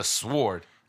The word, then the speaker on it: sword: the sword. – No, you know what? I hate people that pronounce a W in sword.